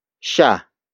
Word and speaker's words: siya: The next word is sha. Sha. Sha. Sha is spelled as S-I-Y-A.